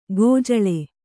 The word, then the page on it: ಕಜ್ಜಳಿ: kajjaḷi